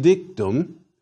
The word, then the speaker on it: dictu: In the fourth declension ablative, the supine appears as dictu with a long u.